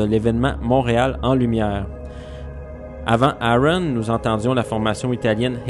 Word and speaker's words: AaRON: Nous entendions la formation française Aaron, qui s'écrit A majuscule A minuscule R-O-N, les trois dernières lettres en majuscule. Donc Aaron, formé des musiciens Olivier Coursier et Simon Buret.